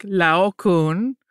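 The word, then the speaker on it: Laocoön: So, some people will say it Laocoon, almost like with harder pronunciation of all of the vowels and consonants. Our wonderful tour guide pronounced it more like Laocoon, which is what a lot of Europeans seem to pronounce it like. So that's what we're going with.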